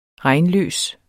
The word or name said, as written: regnløs